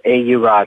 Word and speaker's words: Aeura: It, oh, the website is aeura dot com. – Aura. – And yeah, it's called pronounced aura. It's from an old Sumerian word.